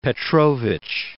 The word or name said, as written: Alexis Petrovich